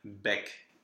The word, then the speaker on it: bec: if you just see a vowel with a C, yes, it will be pronounced. Here we have estoc, which is an old fencing term. – Mm-hmm. – So not very common. You will never use that ever again. and bec. That you will. That's just a word for beak, so that's actually a common word. And both C's are pronounced.